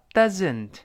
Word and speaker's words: doesn't: 不光是一般的英语学习者，我因为工作的关系呢，接触了好多英语专业八级的，好多英语专业八级的，甚至在美国、英国待了好几年的，也把这个词读成 doesn't，不对，一定浊化 doesn't。